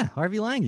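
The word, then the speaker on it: Harvey Langi: Am I saying this right? Harvey Lange? – Yeah, Harvey Lange.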